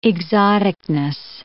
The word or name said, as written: exoticness